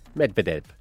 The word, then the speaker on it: Medvedev: Medvedev.